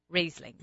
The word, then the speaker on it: Riesling: Late Harvest Riesling. Am I saying it right? – You are saying it right.